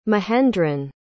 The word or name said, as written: mahendran